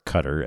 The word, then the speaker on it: Qatar: Mind you, all the Hamas terrorist commanders who ordered these atrocities of October 7th hang out in Qatar, or Qatar as it's sometimes pronounced.